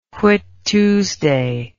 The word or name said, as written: Whit Tuesday